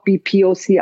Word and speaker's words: BIPOC: Und dann gibt es ein BIPOC, mhm. was aber auch BPOC ausgesprochen wird.